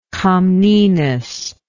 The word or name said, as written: comnenian